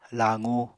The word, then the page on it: laqu: apatani